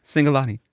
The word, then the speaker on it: Cingolani: Pronounce his name for me, will you? Paul Cingolani. – Cingolani. – I know. Last time you were like, Cingolani. No. It's Cingolani.